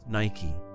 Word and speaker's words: Nike: En anglais, Nike et Nike se prononcent de la même façon. – Nike.